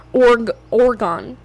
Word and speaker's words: Orgone: Okay, so type in Organite energy pyramid. Energy pyramid. I just want to make sure I'm giving you guys the right pyramid. Or Oregon? Or is it Organite? Oh, Oregon. Oregon. So it's spelled O-R-G-O-N-E.